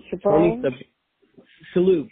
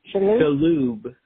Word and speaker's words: Shalhoub: Oh, I told you this, Tony Cibron. – Shalhoub. – How do you pronounce his last name? Shalhoub.